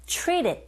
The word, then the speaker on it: treated: 플랩 현상에 의해서 트 사운드가 R 소리에 가깝게 들려지게 되고요. 끝에 ED 같은 경우에는 르, 르, 이런 식으로 받침 소리처럼 들리거든요. Treat it, treat it 이렇게 발음하시면 돼요.